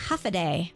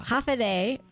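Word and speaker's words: Håfa adai: How do you say aloha in Guam, where I know you used to live? – Yes, and on Guam we would say half a day. So half a day.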